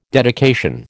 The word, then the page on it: dedication: American English